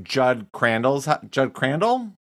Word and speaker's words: Judd Crandall: And the most interesting thing about it was for Judd Crandall, am I saying that right? – Yeah, that's right.